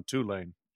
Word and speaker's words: Tulane: How's our pronunciation on Tulane? Doing better, Ross? – Yeah. Yeah. Perfect. – Right. Good. Good. – I think I'm going to go with Tulane to cover in this one.